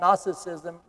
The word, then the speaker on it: Gnosticism: And that's spelled G-N-O-S-T-I-C-I-S-M, Gnosticism.